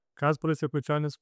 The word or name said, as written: Kasparas Jakucionis